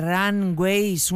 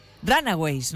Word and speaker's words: Runaways: I love rock and roll, el éxito internacional de Joan Jett, fundadora de Runaways, una de las primeras bandas femeninas de rock y que además hoy está de cumpleaños. ¿Lo he dicho bien? No. Runaways, ¿no? Vale.